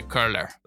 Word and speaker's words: Koehler: Yes, unless you want to pronounce it with the German umlaut, say Koehler.